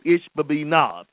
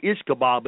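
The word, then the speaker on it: Ishbi-benob: That's how they say it, Ishbibinab. You know, I used to call him Ishkabob, but it's Ishbibinab.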